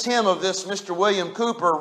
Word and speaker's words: Cowper: And one of my favorite hymn writers, it looks like his name would be pronounced William Cowper. His name is William Cooper, but it's spelled C O W P E R.